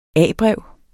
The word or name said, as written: A-brev